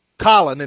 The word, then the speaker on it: Colin: But you mentioned Colin. I think it's Colin, and he doesn't know that he pronounces it colon. I don't think he knows the proper pronunciation is Colin.